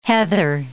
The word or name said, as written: heather